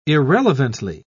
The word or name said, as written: irrelevantly